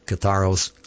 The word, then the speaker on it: katharos: And Yahweh is going to clean us and make us pure, to be separated in Kodesh, but in Greek, Greek word 25:13, katharos, or katharos, K-A-T-H-A-R-O-S, phonetically there, katharos, means to be clean, clear, and pure.